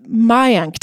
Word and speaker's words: Mayank: Let's start with Mayank.